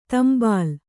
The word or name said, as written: ತಾಂಬೇಲು